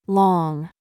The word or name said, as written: lot